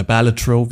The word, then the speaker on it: Balatro: Aktuell in einem Substitutionsprogramm mit Balatro oder Balatro, wie ich gelernt habe, wie es richtig ausgesprochen wird.